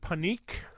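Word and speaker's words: Panik: I've heard panique. I've heard that's how they say it in Slovakia, but I think the American way to say it is panic.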